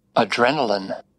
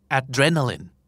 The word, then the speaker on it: adrenaline: adrenaline adrenaline